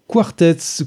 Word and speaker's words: QW4RTZ: C'était bien évidemment la tribu de Dana, de Mano, qui a été reprise ici en version 100% a cappella par Quartets. Ça s'écrit QW4RTZ, donc vas-y le prononcer.